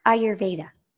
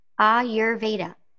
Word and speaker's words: Ayurveda: And to answer your question, it's Ayurveda. – Ayur, pronounce it for us again. Ayurveda. Ayurveda.